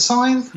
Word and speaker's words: scythe: Is that how you pronounce it? A scythe? – Yeah, that's it.